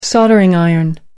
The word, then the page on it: soldering iron: American English